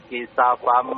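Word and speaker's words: Kisafam (كسفا): Fa'at kita alaina kisafam. – Pendek bacanya. Kisafam. Jangan dipanjangkan sinnya ya. Coba ulangi lagi. – Fa'at kita alaina kisafam. – Jangan dipanjangkan Pak. Kisafam sinnya pendek ya.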